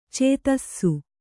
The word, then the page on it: ಛತ್ತೀಸ: Kannada